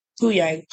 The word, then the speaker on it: Huyang: Can you tell us about Huang? – So. – Did I pronounce that right? – Huyang. – Huyang. Okay. – It's pronounced how Huyang. It looks.